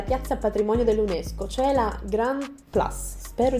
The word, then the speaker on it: Grand Place: Sì, sì, sì, sì, si pronuncia così. mi hanno già sgridato una volta, sono sicura che sia così. In francese si dice Grand Place.